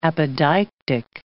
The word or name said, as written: apodeictic